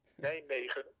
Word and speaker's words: Nijmegen: I work at the Radboud University Medical Center in, this is the correct pronunciation, Nijmegen. They say Dutch is a disease of the throat, and Nijmegen is an excellent word to demonstrate this.